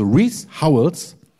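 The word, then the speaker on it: Rees Howells: Reese Howells, schreibt man R-E-E-S und dann Hovels, also Reese Howells, hat ein Buch über Fürbitte geschrieben.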